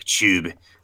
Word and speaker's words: Chubb: Nicholas Schub. He wants... yes, they are very good in when they play the football. So, yeah, Nick Chubb.